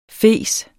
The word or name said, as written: fes